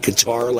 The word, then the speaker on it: Qatar: And it shows the amount of money that countries like Qatar, I think that's how you pronounce it, right? Qatar, like guitar.